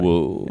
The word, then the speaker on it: Wu: No, it's pronounced differently. – Wu. – Yeah. – The accent goes down, not up.